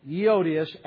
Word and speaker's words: Euodias: And so, okay, look, it says in verse 2, I beseech Euodias, by the way, I looked it up to make sure I pronounced that correctly today, Euodias and Syntyche, that they be of the same mind in the Lord.